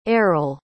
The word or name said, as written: errol